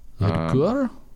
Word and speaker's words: Ladouceur: Marc Ladouceur, is that how you pronounce it? L-A-D-O-U-C-E-U-R. Ladouceur? – Yeah, close enough.